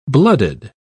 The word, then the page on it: bloodied: British English